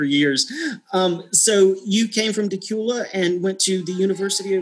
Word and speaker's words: Dacula: I'm originally from a town called Decula, Georgia. It's spelled D-A-C-U-L-A. Decula.